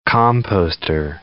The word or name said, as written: composter